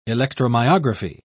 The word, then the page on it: electromyography: British English